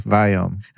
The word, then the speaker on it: Viome: Indians cannot pronounce the word we. – V, yeah. So I'll say it for you. Viome. – So, so how do you spell that? – Yeah. V-I-O, is it two M's? One. M-M-E, one M. V-I-O-M-E, Viome. So, like biome, like gut biome, but Viome.